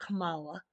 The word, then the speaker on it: Kamala: Kamala,